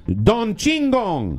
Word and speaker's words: Chingon: And finally, a little something, a Brooklyn taqueria called Don Shingon is offering an incredible, did I pronounce that? C-H-I-N-G-O-N. – Chingon? – Don Chingon.